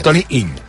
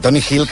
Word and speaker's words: Toni Hill: dir Toni Hill que Toni Hill. Sobretot aquesta ella final, a la que surts d'aquí, costa molt de dir.